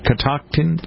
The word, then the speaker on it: Catoctin: Catoctin? – Yeah. – Is that how you pronounce it? C-A-T-O-C? – Yeah.